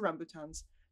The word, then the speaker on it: rambutan: from bananas to rambutans. I didn't say that correctly. Whatever. – No, rambutan is how you pronounce it.